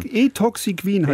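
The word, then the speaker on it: Ethoxyquin: Etoxiquin heißt das Zeug.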